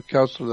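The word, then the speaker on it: Koestler: Second question is, what do you think of the work of Arthur, I guess that you pronounce that Kostler, Arthur Kostler, the 13th tribe.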